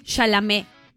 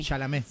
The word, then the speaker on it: Chalamet: Chalamet, que lo pronuncié mal. ¿Cómo es Timothy? – Chalamet. Creo que es así. – Chalamet. – Se debería pronunciar así.